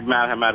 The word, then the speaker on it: Marhavat: It's actually Marhavat is the last name, and the site is actually Hapu.